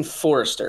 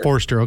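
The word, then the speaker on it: Foerster: And I've heard the name both ways, Tyson Forster and Tyson Forster. How's the pronunciation go? – Yes, it's Tyson Forster. – Forster, okay.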